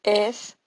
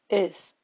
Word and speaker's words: is: Acuérdense que el is en inglés es is, pero no se dice is. Es algo entre la E y la I. Es una nueva vocal que no se usa en español.